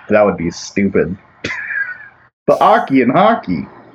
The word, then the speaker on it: oche: O-C-H-E. – O-C-H-E. – Pronounced Aki.